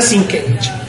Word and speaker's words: Sienkiewicz: Es Sienkiewicz con Z al final y K. – Lo sabía. – Pero se pronuncia Sienkiewicz.